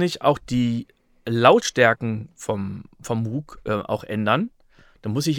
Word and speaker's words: Moog: Das klingt auf Deutsch, würde es mal doof klingen. Ne? Ich habe den Moog Sub 37. – Moog. – Ja. Ja, deshalb spricht man das halt immer so.